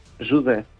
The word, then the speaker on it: José: Pero José en portugués, José. – Jude. – ¿Cómo? – Jude. – Jude. – Jude. – Pues como Jude, como dice Jude.